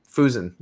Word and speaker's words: Fusen: So Noah Fussen. Fusen. Okay. – I literally like listened to the podcast just to hear his pronunciation so I can nail it on the show.